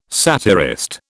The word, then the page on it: satirist: British English